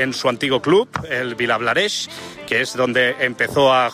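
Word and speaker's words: Vilablareix: Gracias, desde Villablareix. Ahora sí que lo he dicho bien.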